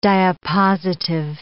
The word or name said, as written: diapositive